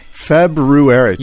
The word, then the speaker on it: February: I loved your alliteration of the word February, by the way. – February. – You just slipped it in there. Like, tough word to say. – Tough, but you said it correctly.